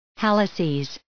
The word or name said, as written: halluces